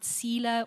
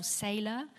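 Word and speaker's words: Selah: This Hebrew word called, you know, some pronounce it sealer or sailor.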